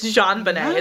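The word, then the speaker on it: JonBenét: It's JonBenet. It's French.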